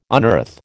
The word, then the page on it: unearth: American English